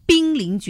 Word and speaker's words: 濒临: 那么一般来讲呢，看到那个就是它要读成濒临绝种，